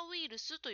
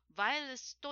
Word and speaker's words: ウイルス: それからコロナウイルスという言葉ですが、ジムさんはバイルスと言っていました。これは英語の発音ですから、日本人の人には通じないと思います。日本語ではウイルスと言います。